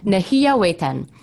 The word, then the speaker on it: Nehiyawetan: Nehiya did I say that correctly? Mm-hmm. Okay, she's nodding. I did, I did. Nehiyawetan, both on APTN.